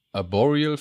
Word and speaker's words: Arboreal: Ein Forschungszentrum tief im, oh Gott, wie spricht man das aus? Arboreal Forest? Ja. – ja? Stimmt, ja.